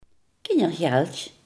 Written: kinderbijslag

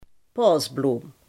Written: narcis

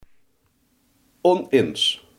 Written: oneens

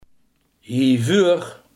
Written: hiervoor

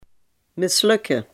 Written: mislukken

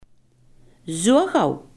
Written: zodra

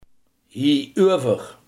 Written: hierover

